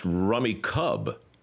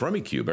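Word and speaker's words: Rummikub: Although my friends from Australia came by the other day and told me that it's pronounced Rummy Cub. And we had this whole argument about it. Because, like, no, it's Rummy Cube. Everybody calls it Rummy Cube. And he said, well, how do you spell it, mate? And I said, well, I got the box down and looked at it. And there's no E at the end of it.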